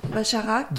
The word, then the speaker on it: Bacharach: Donc alors, Bacharach. – Burt Bacharach. – Oh, yeah. – Bacharach.